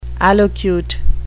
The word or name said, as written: allocute